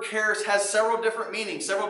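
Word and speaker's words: charis (χάρις): First off, the word grace in the Greek is a word that we pronounce as, as charis. Charis. That is what grace is in Greek.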